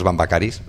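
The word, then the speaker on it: Vamvakaris: Es el caso de uno de los maestros de esta música, Marcos Bambakaris, ¿lo he dicho bien? – Muy bien.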